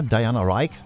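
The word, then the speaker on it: Diana Rigg: Und dann möchte ich noch erinnern an Diana Rick. Ja, Diana Rick, vielmehr ausgesprochen, ne? Ist ja Englisch.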